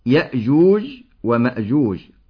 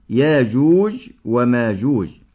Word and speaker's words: يأجوج ومأجوج: ينطق لفظ يأجوج ومأجوج بالهمز وبدونه، فنقول يأجوج ومأجوج، ونقول أيضا ياجوج وماجوج.